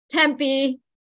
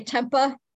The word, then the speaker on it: Tempe: Tempe. They say Tempa.